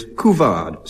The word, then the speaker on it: couvade: And now, Anne Scott James, your word is couvade, C-O-U-V-A-D-E, and in spite of my pronunciation, you'll perhaps recognize it's French in origin, couvade.